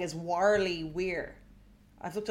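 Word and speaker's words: Warleigh Weir: And I think Worley Weir, I don't think you're crazy.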